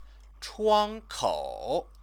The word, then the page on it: chuankou: chinese